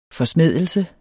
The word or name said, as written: forsmædelse